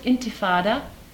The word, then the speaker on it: Intifada: The Arabs have given it an Arabic name, which is pronounced Intifada,